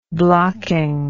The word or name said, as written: blocking